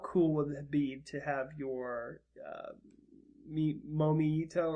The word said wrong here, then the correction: Miitomo: The Miitomos? – How cool would it be?